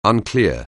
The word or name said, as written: unclear